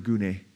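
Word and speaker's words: gune (γυνή): And others would say, and by the way, the word gune there, gune, G-U-N-E would be how you transliterate it from the Greek, but you pronounce it gune.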